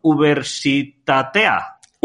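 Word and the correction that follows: Unibertsitatea: Uy, casi. Universitatea, es universidad en euskera.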